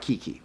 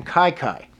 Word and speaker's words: Kiki: It's Kiki, but it's not Kiki. They call him Kai Kai. – Okay. – It is K I K I, but I looked it up. It's Kai Kai.